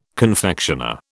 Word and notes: confectioner: British English

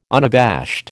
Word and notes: unabashed: American English

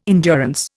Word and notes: endurance: Indian

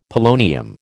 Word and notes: polonium: American English

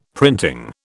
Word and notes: printing: British English

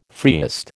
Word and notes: freest: American English

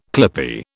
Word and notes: clippie: British English

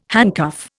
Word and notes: handcuff: Indian

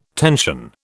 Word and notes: tension: British English

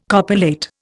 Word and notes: copulate: Indian

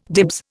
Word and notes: dibs: Indian

